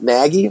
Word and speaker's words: Nagy: The Bears find their guy, Matt Nagy.